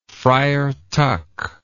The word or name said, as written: Friar Tuck